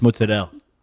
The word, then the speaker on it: mozzarella: If she's from New Jersey and Italian, you don't pronounce the A at the end of the word. It's just mozzarella.